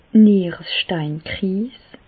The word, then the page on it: d' Nieresteinkriis: Alsatian; Bas-Rhin; Reichshoffen